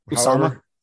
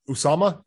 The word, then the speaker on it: Uzomah: But CJ Uzomah? – Yeah. – Uzomah? Sorry. – Usama. – Usama, there it is.